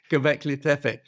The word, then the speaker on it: Göbekli Tepe: Göbekli Tepe.